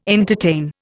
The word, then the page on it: entertain: Indian